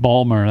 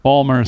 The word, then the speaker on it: Baltimore: Balmer, that's how they say it. Yeah. – Balmer. – Baltimore. – I don't know if either of those is correct. – Balmer is correct.